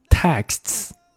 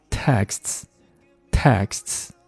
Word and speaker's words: texts: a lot of texts。首先看这个单词很烦啊，这个单词短信叫做 text，text，text。那当它加 s 的时候，变成复数的时候，要读成 texts。这个音我建议大家稍微练一练啊，读起来还挺难受的。 texts，texts。